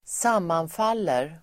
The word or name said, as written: sammanfaller